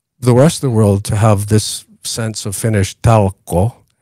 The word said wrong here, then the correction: talkoot: Talkoot. – Talkoot. – There's a T in the end.